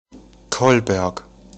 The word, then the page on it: Kolberg: German